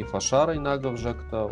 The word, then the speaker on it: Фашара: Э, и фашара инагов жакта у нас э, ударение на второй слог, на ша.